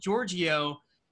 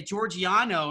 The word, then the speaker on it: Giorgio: So Giorgio, as we spoke earlier, it's Greek. Mm-hmm. And when I used to have teachers pronounce my last name, they would say Giorgiano, and I'm like, I didn't know there was an N in my name.